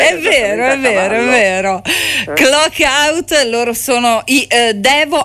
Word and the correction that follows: Devo: anzi, si pronuncia Devo o Divo? Chiedo a te. – Si pronuncia Divo. – Divo, perfetto. Ammetto subito la mia ignoranza sulle pronuncia di determinati gruppi.